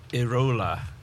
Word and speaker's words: Iraola: And Andoni Iraola. – There you go. I pronounced his second name wrong and was corrected. – Am I saying it right? – Yes, you're saying it right, but I was corrected because I confused it with something else and when someone explained what it was.